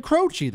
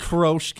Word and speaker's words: Croce: Jim Croce, that's how you say his name. Yes, how did you think you say his name? It's not Croce.